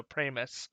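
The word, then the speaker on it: Pramas: Pramus, I think is. He says his name rhymes with famous, so Pramus.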